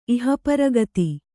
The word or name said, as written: ಇಹಪರಗತಿ